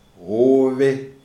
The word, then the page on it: Hove: Shona